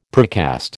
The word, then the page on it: precast: American English